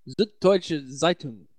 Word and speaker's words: Süddeutsche Zeitung: Süddeutsche Zeitung is the newspaper.